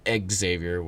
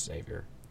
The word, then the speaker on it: Xavier: He said, quote, in a preview press conference talking about crosstown, said, listen, we know going to Norwood, comma, going to Xavier, pronounced Egg Xavier, which, by the way, it's just Xavier,